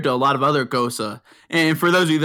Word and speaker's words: Gose: It is a type of beer spelled G-O-S-E, but pronounced like the soccer player Mario Götze. It's like Gosa.